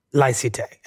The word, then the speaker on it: laïcité: which is called laïcité. – And what is laïcité? Am I saying it correctly? Laïcité. – Laïcité, yeah. That's fine this way.